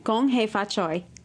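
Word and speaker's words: Gong hei fa choy (恭喜發財): Gong hei fa choy.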